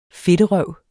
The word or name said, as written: fedterøv